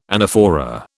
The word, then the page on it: anaphora: British English